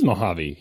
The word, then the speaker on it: Mojave: It is Mojave. He was right.